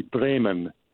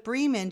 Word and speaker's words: Bremen: I just wanted to make a gentle comment about the came up about the correct pronunciation of the town Bremen, B-R-E-M-E-N. The fully correct German pronunciation would be Bremen, not Bremen.